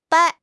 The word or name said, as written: 掰